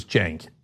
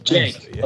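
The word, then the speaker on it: Cenk: And the C is pronounced like a J in my case, so it's Jank. But Jank.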